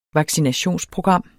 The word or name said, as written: vaccinationsprogram